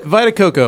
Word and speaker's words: Vita Coco: Is that how you say it? – Vita, yeah, Vita Coco.